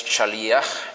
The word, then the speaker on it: shaliach: Y la palabra en singular es shaliach, con acento en la i, y en plural shlichim, con el acento en la última i, shlichim, como aparece aquí.